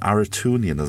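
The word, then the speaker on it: Arutiunian: 5, and Aratunian. Am I pronouncing that right? – That's the right one, yeah.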